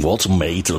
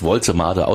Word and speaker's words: Woltemade: Nick Woltemade, übrigens will man immer das gleich Englisch aussprechen, Woltemade oder so, aber er wird wirklich Woltemade ausgesprochen.